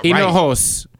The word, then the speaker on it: Hinojos: And so right now I noticed I said Maldives Hinojas or some shit. – Oh. – And I noticed I said it wrong. I was like, wait, that's not Hinojos. – Right.